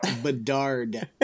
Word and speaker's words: Bedard: Am I saying his name right? Now I can't say it. – Connor Bedard. – I did say it right.